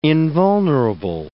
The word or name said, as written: invulnerable